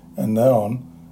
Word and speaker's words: Aenon: And then John 3:23, and John was baptizing in Aenon, and I suppose you pronounce it Anaon, near Salem, in other words, the location where it was, because there was much water there.